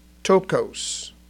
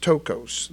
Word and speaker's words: ptochos: Tokos, and it's spelled P-T-O-C-H-O-S, pronounced Tokos, the P is silent, P-T-O-C-H-O-S.